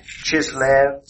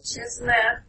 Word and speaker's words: Chislev: Now, it happened in the month of Chislev. – Chislev, in the 20th year while I was in Susa, mm-hmm.